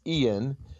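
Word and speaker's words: Eoin: I think it's Ian Colfer, although it's spelled in an unusual way, describes the novels as Die Hard with fairies.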